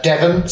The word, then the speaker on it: Devon: From Devon. Still rhymes with heaven.